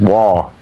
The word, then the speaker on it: Waugh: it's war, W-A-U-G-H, rather than the English spelling.